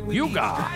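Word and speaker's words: hygge: Yuga. Yeah. – Yuga. – It's H-Y-G-G-E. It's pronounced yuga, mm-hmm. A Danish concept.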